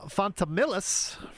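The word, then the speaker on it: Fontamillas: Jerome Fontamillis from Switch.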